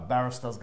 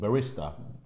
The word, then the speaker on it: barista: Like a barrister's going to make me a coffee. A barrister. The guy that makes the coffee behind the thing. – Barista.